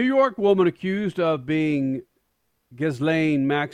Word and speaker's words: Ghislaine: but Is her name pronounced Ghislaine Maxwell Crasher? – Ghislaine. – Ghislaine Maxwell. – Isn't it, Stat? – Yeah, Ghislaine, yeah, right.